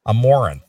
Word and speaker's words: Amouranth: Amaranth, like Amore, as in like yeah. in love, Amaranth.